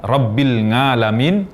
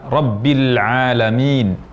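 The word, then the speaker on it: Rabbil Alamin (رَبِّ الْعَالَمِينَ): Jadi mengucapkan Rabbil Ngalamin, ini kesalahan. Yang benar Rabbil Alamin, Rabbil Alamin.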